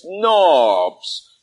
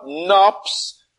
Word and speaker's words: knobs: In the scripture, it's spelled with a P, but that's an error. It's knobs, not knops, but knobs. The old way of calling it a knob was knops, but it's really a knob.